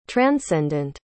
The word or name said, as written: transcendant